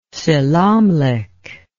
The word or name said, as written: selamlik